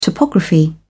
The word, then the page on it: topography: British English